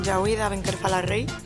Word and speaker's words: Djaouida Benkherfallahrey: Sí, porque en estos temas del bereber soy un tanto neófito, así que casi mejor que digas tu nombre con la pronunciación correcta. Y... – vale. Bueno, me llamo Yahuida Benkerfala Rey, me llaman Yahuida, claro, para bailar, mi nombre artístico y real, claro.